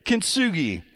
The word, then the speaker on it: Kintsugi: Let's skip down to Kintsugi. I think is how you say it. Kintsugi. Kintsugi, folks.